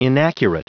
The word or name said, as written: inaccurate